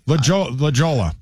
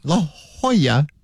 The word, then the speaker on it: La Jolla: Now, I know for a fact that you have pronounced La Jolla properly. – La Jolla. – Yeah. It's the same thing. La Jolla, J-O-L-L-A.